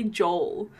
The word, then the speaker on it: Joel: Last 2 weeks ago, I think, I was watching, I told her I was watching the show called Santa Clarita, and the husband's name is spelled J-O-E-L, but they say Joel. So yeah, I was like, here. Is it actually? – Yeah, I've given up to saying Joel.